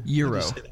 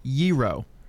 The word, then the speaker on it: gyro: I get ideas, but I remember I actually, there, you know, the, what is, how do you say gyro? Is it G-Y-R-O? – Euro. Ye. What? Like what? It's like it's a Y. Euro. – Euro.